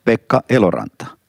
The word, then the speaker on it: Pekka Eloranta: You are Pekka Eloranta, so I would like you later to say your name with the Finnish accent. I guess it sounds a bit different. – Yeah, but you did it rather well. It's okay. – Pekka Eloranta.